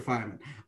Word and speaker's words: Feynman: I always forget how he's pronounced. Feynman, I think. Feynman, yeah. Okay.